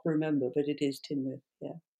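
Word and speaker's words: Tŷ Newydd: I thought it was Tynwyd. – It's spelled T-Y and then new word N-E-W-Y-D-D, but it's actually Tynwyth.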